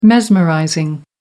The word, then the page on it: mesmerizing: American English